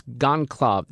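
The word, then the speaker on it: Goncalves: Do you know how to pronounce her name? Is it Kuko? – No, it's Gonclave.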